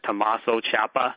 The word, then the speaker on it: Tommaso Ciampa: Jay Lethal turned heel, and he defeated, I apologize if I botched his name, I think his name is Tommaso Ciampa. I think I got his last name wrong. – No, you nailed it perfectly.